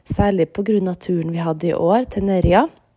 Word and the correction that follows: Nerja: Eh, det uttales jo egentlig Nerja, men jeg orker ikke å si det nå, for jeg har så vondt i halsen.